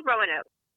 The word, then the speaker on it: Roanoke: It's called Roanoke.